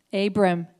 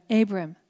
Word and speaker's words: Abram: He was Abram. Abram. Abram. That's how you say it.